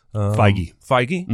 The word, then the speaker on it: Feige: Feige? – Mm-hmm.